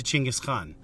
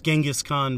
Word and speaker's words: Chinggis Khan: Chinggis Khan, better known as Genghis Khan by those who don't know the proper pronunciation.